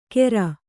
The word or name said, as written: ಕೇರ್